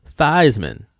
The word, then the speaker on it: Theismann: They had him change the pronunciation of his name to rhyme with Heisman. So now he's Joe Theismann.